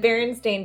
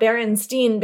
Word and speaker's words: Berenstain: A lot of people remember it as S-T-E-I-N, and it's actually S-T-A-I-N. And I remember pronouncing it myself, Berenstain Bears. But no matter how weird the English language gets, A-I is never pronounced E.